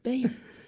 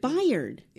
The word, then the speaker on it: Bayard: Now you rhymes missed. With inspired. – Inspired. – Rhymes with inspired. – Bayard. – That's what he said. He would say it. Bayard. – Bayard. Inspired. Yes.